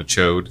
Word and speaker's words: Chode: He's like giant amphibious, but his name is Chode, which I always pronounce it as Chod.